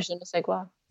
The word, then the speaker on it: je ne sais quoi: Yeah, je ne sais quoi.